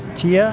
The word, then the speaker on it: Tia: Tia, how do you spell that? – T-I-A. – T-I-A, simple.